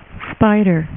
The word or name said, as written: Spiderman